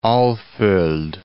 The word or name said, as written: Alfold